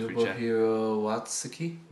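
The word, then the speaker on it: Nobuhiro Watsuki: The Megacon is no other than Nubushuri Watsuki. I can't pronounce it. – Nubuhiro Watsuki. – There you go.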